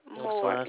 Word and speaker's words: Moore: Carolyn, Carolyn Moore, Moore. – You said Carol, and spell that one more time for me. – Carolyn. Moore, M-O-O-R-E. – What's the address? – 2311 Norris, that's N-O-R-R-I-S, Circle, C-I-R-C-L-E.